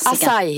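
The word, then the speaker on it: açaí: Acai heter det. – Acai heter det.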